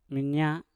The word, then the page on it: minya: Mpur